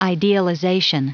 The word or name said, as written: idealization